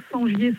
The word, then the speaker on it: Sengier: Sur mon nom, Sangier Sandrine. Et voilà, il y a tout. – Sangier, ça s'écrit comment? – Alors, S-E-N-G-I-E-R.